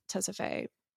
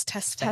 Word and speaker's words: Tesfaye: Seems like she goes to this nightclub, meets this nightclub owner, aka Tessa Faye. I don't even think I'm pronouncing his. – I think it's Tess Faye.